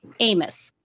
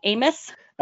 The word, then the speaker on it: AMIS: Today, we have a special guest, an advocate for immigrants and asylum seekers working with Americans Making Immigrants Safe, AMIS. Did I say that right, AMIS? – It's kind of a play on the French word for friends, so we pronounce it AMI.